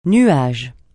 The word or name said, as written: nuage